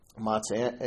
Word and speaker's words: matzo: Matzah. Matzah, M-A-T-Z-O. That's how they spell it.